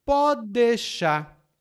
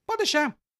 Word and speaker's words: pode deixar: Lá no guia de aprendizagem você vai ver algumas informações sobre isso, mas aqui eu digo para você: a pronúncia dessa expressão é um pouco diferente. Em palavra é pode deixar, mas nós combinamos as palavras e dizemos pode deixar com três sílabas. Pode deixar. Hum? Pode deixar comigo. É assim que nós dizemos. Pode deixar. O pode deixar vira pode deixar.